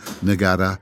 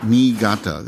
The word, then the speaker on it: Niigata: Now, if you look at it in Romaji, in Roman letters, it's N-I-I-G-A-T-A. Very difficult to pronounce for a lot of people, you know, Niigata or Niigata, but Niigata. And what you do in Japanese is the E sound, you have to pronounce, you have two I's, and those are pronounced with an E sound, so you have to double it.